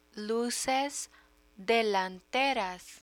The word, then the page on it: luces delanteras: Spanish